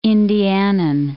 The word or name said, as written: Indianan